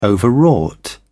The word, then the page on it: overwrought: British English